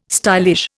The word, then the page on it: stylish: Indian